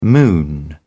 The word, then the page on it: moon around: British English